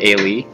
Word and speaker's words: Ailee: Ailee. I like the fact that Ailee's in here. There's not much else I can say about it. – At least you're pronouncing Ailey right this time.